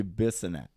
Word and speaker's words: Bissonnette: That's how they say it. The foreigners say it. It's actually Bissonnette.